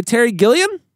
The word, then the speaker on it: Gilliam: It was directed by Terry Gilliam.